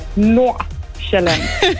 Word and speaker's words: Nordjylland: Nordjylland. – that's a really good example. Nordjylland. And perfect. The D at the end is pronounced as well. – The D isn't pronounced. No, it's silent.